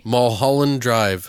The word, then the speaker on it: Mulholland Drive: She was in Mulholland Drive. – Mulholland Drive. – We say that wrong. Say it for me. – Mulholland Drive.